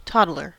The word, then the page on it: toddler: American English